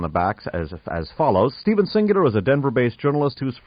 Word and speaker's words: Singular: Singular is spelled just like the word, S-I-N-G-U-L-A-R.